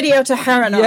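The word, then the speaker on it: Yedioth Ahronoth: But you're right to point out that Israeli media, Israeli's top newspaper, and I forgive me, I think it was, I don't know how you pronounce it correctly. – It's Yediot Aharonot.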